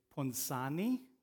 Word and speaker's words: Ponzani: It's Ponzani. That's the correct Italian pronunciation.